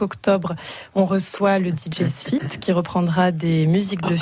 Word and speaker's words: SFIDT: Non, ça s'écrit S-F-I-D-T. – D'accord. – Ça se dit Sfit.